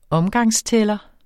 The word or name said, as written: omgang